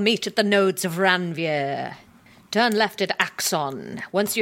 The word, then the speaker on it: Ranvier: R-A-N-V-I-E-R. Ranvier. – R-A-N-V... – I-E-R. – Yeah, Ranvier.